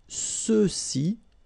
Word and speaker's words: ceux-ci: And then for the masculine plural, ceux-ci, okay, remember final X is not pronounced, so you get the sound ce, ceux-ci, and then ceux-là, all right?